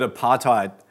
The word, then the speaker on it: apartheid: Apartheid is an Afrikaans word, and apparently you pronounce the D as a T.